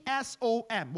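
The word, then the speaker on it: som (צום): Because the biblical definition for fast, the word fast or fasting in Hebrew is spelled T S O M. Well, the phonetic is spelled T S O M. It's som. Som right? Sounds like the, the song.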